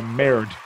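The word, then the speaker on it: Merteuil: Catherine Meritwheel? Cruel Intentions? – You don't pronounce the L for sure. – Meritwheel. – Melt. – Yeah, Merit.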